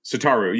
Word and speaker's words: Satoru: And if you don't have Eurekas and want to play blue-black Show and Tell, you can play Sotowaru. Is that how it's pronounced? – Satoru, yeah.